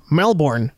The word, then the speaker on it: Melbourne: You gotta say it right, because they get mad. Melbourne.